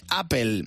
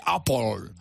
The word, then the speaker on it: Apple: Apple también mal, porque la E final, tenemos un problema con las S en Spain, porque la E final también debería ser una E más cerrada, no se dice Apple. – Se dice Apple.